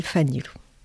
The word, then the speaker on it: Fanilo: Là, je, voilà, une société qui s'appelle Fanilou. Qui s'appelle Fanil, ça s'écrit Fanilo, si on malgache.